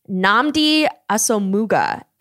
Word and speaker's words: Nnamdi Asomugha: It's Nnamdi Asomugha. And you got it.